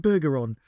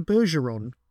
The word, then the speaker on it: Bergeron: Now, I always thought it was called Harrison Bergeron, but apparently in the episode, in the film itself, they call him Harrison Bergeron, like Bergerac.